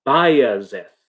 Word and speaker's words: Bajazeth: In the Oxford reference of Shakespearean pronunciation, Bajazeth, I think that's it. So it's a Y sound.